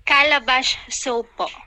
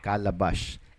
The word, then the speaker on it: Calabash: Ma'am, Itatanong ko lang po yung bagong labas ng product ng Inline Asia Corporation, yun pong Calabash Soap. Calabash ba? Ano, what is the right pronunciation po? – Calabash Soap po. – Calabash. And ang spelling po nun ay? – C-A-L-A-B-A-S-H. Calabash. – Calabash talaga na.